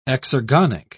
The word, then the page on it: exergonic: British English